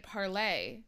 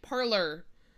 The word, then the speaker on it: Parler: But they're not pronouncing it parlay. They're pronouncing it parlor.